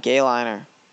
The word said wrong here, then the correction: guyliner: No, not... Guyliner.